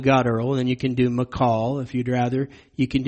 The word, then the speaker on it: Michal: All right, first of all, the name Michal, that is the feminine version of Michael, and it really should not be pronounced Michael. I've heard people do that. They'll take this name here in 1 Samuel 21, and they'll pronounce it Michael. I've probably even done that before, but it actually is pronounced Michal.